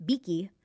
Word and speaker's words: Vicky: But also my name in Spanish, if you pronounce Vicky, it's Beaky.